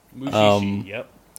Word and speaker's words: Mushishi: You know, it was a manga series by Shuzo Oshimi, and they ended up making an anime adaptation in 2013 by the Mushishi. Is that right? That's how you say it, right? – Mushishi.